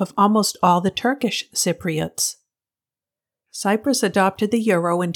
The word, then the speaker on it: Cypriots: The inhabitants are called Cypriots, with the first syllable containing the P sound.